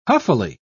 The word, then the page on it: huffily: British English